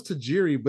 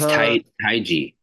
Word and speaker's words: Taiji: I always thought it was Tajiri, but there's no R. It's, I think it's Taiji.